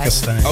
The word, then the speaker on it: Uzbekistan: Am I pronouncing this right? Uzbekistan? – Yeah. – Uzbekistan.